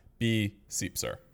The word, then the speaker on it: BSiepser: You can find me on all the major social media networks at B-Seepser. – How do you spell that? – Spell that B-S-I-E-P-S-E-R.